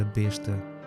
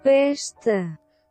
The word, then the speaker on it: besta: pronunciei mal a palavra besta, quando na realidade é besta, sendo o móvel sueco pastor.